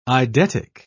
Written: eidetic

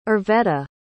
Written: irvetta

